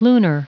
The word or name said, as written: lunar